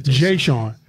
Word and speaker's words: Jeshawn: Am I pronouncing your name correctly? – Jeshawn. – All right. Jeshawn Stevenson. My apologies.